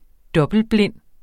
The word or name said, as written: dobbeltblind